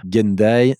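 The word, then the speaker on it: Gendaj: Donc Mathieu avec un T, M-A-T-H-I-E-U. Gendaye, c'est... Donc Gendaye déjà, personne ne le prononce correctement. Donc c'est G-E-N-D-A-J, J comme Jean, voilà.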